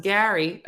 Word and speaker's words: Gerry: Jerry, pronounced Gary. Okay. The G-E-R-R-Y, pronounced Gary.